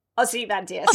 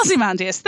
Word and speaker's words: Ozymandias: So we then cut to Morse listening to the radio later, and they mention the poem, which I cannot pronounce. – Ozymandias. – Ozymandias, thank you very much.